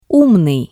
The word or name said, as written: umnii